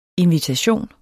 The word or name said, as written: invitation